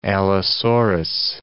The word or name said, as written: allosaurus